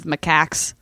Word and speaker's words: macaques: By the way, a lot of people did write in to say that, I think we talked about this already, but you were correct in the pronunciation of macaques.